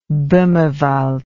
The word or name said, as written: bohmerwald